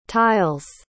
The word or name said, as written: tile's